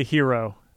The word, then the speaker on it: gyro: It's spelled gyro. And it's not yet. – I prefer to say hero.